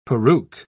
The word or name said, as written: peruke